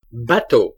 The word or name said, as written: bateau